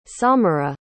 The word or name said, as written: samirah